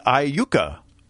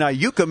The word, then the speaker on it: Iuka: Justin in Iuka. Did I say it correctly? – You did say it correctly. – Why, thank you. In Iuka, Mississippi.